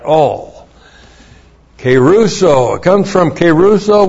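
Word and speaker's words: keruso (κηρύσσω): Three, it comes from the verb keruso.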